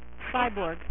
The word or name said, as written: Cyborg